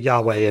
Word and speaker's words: Yahweh: because there's no vowels, but in theory you would pronounce it Yahweh in Hebrew.